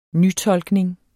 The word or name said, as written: nytolkning